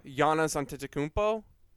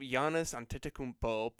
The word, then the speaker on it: Giannis Antetokounmpo: Like, you shut down Giannis Antetokounmpo because, like, the majority... – Did you say his name right? – Giannis Antetokounmpo? Thanks, buddy. I appreciate that.